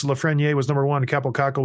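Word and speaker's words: Lafrenière: So it's going to be Lafreniere. That's how you say it, by the way.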